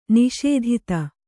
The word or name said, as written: ನಿಷೇಧಿತ